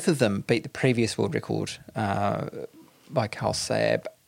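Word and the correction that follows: Karel Sabbe: Carol Sabe.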